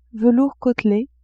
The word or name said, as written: velours côtelé